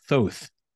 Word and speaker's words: Thoth: once you're able to give us the story of Thoth. If I'm saying that correct, right. I believe I am. – That is the correct pronunciation.